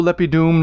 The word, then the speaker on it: lepidum: Qui dono lepidum novum libellum. Count that on your fingers. Qui dono lepidum novum libellum. 11 syllables. Qui dono. Long, long, long. Lepidum. Short, short, long.